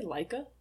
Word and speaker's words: Leika: L-E-I-K-A? How do you say that word? – L-E-I-K-A. I would say Leica.